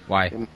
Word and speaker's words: Y: Será como é que fala Y em inglês. Why?